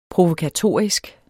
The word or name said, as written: provokatorisk